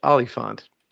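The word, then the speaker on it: Oliphaunt: Yeah, and I believe it's pronounced Oliphant.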